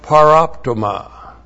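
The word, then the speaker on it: paraptoma: And then we have paroptoma. Now you're used to seeing hamartia, sin. This is paroptoma, P-A-R-A-P-T, long O-M-A.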